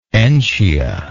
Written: entia

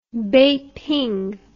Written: beiping

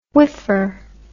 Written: whiffer

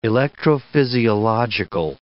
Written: electrophysiological

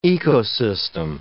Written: ecosystem

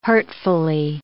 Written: hurtfully

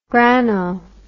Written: grano

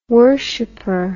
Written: worshiper

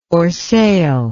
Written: orseille

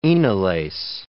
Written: enolase

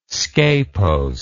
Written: scapose

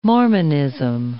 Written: Mormonism

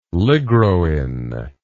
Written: ligroin

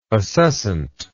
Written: acescency

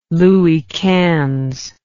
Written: Louis Quinze

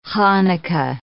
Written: Chanukah

